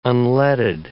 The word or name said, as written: unleaded